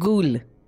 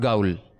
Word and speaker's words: Ghoul: Then we have, I don't know how to pronounce the word. – Ghoul. – Not Gaul. Okay.